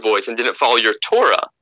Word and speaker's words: Torah: And hinting at the two Torahs are written in Oral Torah, but it's pronounced Torah. If you just read it, you know, it's read uh, is read aloud as Torah in the singular.